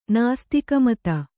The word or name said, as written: ನಾಸ್ತಿಕಮತ